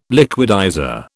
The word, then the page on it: liquidizer: British English